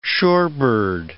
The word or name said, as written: shorebird